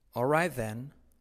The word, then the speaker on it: alright: Alright then. O, letra clave L, casi no se pronuncia.